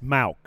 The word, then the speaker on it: Mauch: How do you say Cody's last name? Mauch. – Mauch. Cody. Yeah, that's why. Mauch? That's why I asked. It's, it's Mauch. Cody Mauch.